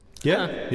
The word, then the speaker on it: ye: And so the ye is actually supposed to be pronounced the.